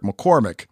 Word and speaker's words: McCormack: You guys continuously refer to him as Eric McCormack when his last name has an A, not an I in it.